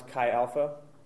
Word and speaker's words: Chi Alpha: Last one, number 10, biased, obviously, how to pronounce Chi Alpha. It's C-H-I, second word, A-L-P-H-A. It's like Aloha, but switch the O for a P, okay?